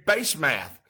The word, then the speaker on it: Basemath: Then he married Basemath. And I can spell that because it's Base, B-A-S-E, and Math, M-A-T-H.